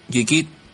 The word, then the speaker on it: Geekit: A ver, Geekit se dice Geekit, no, Geek de Geekiados y del payaso Pennywise y, y P de Perú.